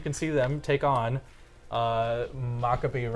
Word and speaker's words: Maccabi: Well, it turns out the Hebrew pronunciation is Maccabi, not Maccabi. So it's they're playing Maccabi Ra'anana.